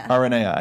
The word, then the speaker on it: Aranea: Aranea. I did say it right.